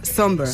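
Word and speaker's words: Sombr: Pues mira, yo busqué cómo se pronunciaba por si acaso, pero efectivamente se pronuncia Somber.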